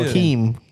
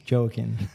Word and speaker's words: Joaquin: You should not Keem. – Joaquin. – Joaquin. Were you going to say Joaquin? – Yeah, Joaquin.